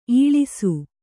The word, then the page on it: ಈಳಿಸು: īḷisu